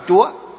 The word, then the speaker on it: chủ: Giáo chủ, giáo tức là người thầy, chủ tức là ngày xưa chủ, chứ chủ ngày xưa còn đọc là chúa.